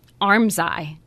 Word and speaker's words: armscye: So, I'm going to spell it first because it's spelled A-R-M-S-C-Y-E. But it's pronounced Armsye.